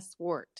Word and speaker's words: Swart: And that was when we listened to that episode with Dr. Swart, right? Am I saying her name right? – Yep. Dr. Tara Swart.